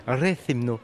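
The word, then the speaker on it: Rethymno: de Retimno. Rethimno. Tu y es presque, tu y es presque. Rethimno. Ah oui, j'ai oublié de, laisser traîner la, la syllabe. Rethimno. Voilà, très bien, bonne prononciation.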